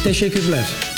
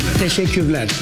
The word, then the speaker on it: Teşekkürler: How do you say thank you in Turkish? – Teşekkürler. – Teşekkürler. – Teşekkürler. Yes. – Teşekkürler. – Like a tissue paper.